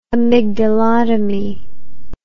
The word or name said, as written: amygdalotomy